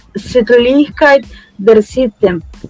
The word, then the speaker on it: Sittlichkeit der Sitte: O tradutor, em determinados momentos, para essa palavrinha que eu não consegui pronunciar, como é que se pronuncia isso daí? Me ajuda aí. – Sittlichkeit der Sitte.